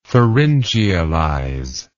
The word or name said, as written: pharyngealize